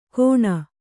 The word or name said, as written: ಘೋಣ